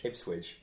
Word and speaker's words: Ipswich: You keep saying Ipswich. – Ipswich. – You say Ipswich with a G. – So what? Ipswich. – Ipswich. Ipswich. – Ipswich. – Better. – Ipswich. Ipswich.